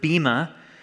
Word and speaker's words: bema: It's the Greek word bema.